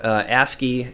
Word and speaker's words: ASCII: I'm going to mispronounce it, ASCII portraits, or do you say ASCII? – Oh, ASCII and ANSI, yeah.